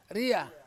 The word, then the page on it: ria: Ansus